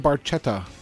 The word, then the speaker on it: Barchetta: By the way, Getty pronounces it incorrectly. – Okay. – He calls it red barchetta. The actual Italian pronunciation of that word is Barchetta. – Barchetta. – Barchetta. – There you go. And that's right from the lips of Neil himself, so.